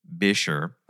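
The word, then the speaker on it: Buescher: Excuse me. Bisher. – Okay. – That's how the Busher players pronounce it. So, all right, fine. – Busher players, Bisher.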